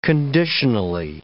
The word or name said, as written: conditionally